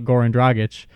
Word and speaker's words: Goran Dragic: Apparently, according to my terrible memory and just rambling and talking about Goran Dragic. Did I pronounce it right? I don't know. – Yes, you did.